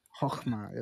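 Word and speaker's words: chokhmah (חכמה): Chokhmah.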